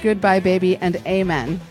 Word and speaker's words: Goodbye Baby and Amen: They pronounce it Goodbye Baby and Amen. They put the emphasis on the first syllable.